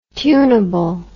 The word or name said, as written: tuneable